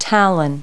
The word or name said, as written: talon